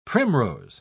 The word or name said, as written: primrose